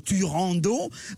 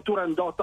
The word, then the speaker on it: Turandot: Allora, il titolo è Turandot all'italiana, così okay. La pronunciava Puccini con i suoi collaboratori, anzi Turandotte in certi casi, addirittura con la doppia T e la E finale in qualche lettera, in maniera un po' così amichevole la chiamavano Turandotte, ma l'opera è Turandot all'italiana.